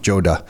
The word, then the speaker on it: Joda: Joda.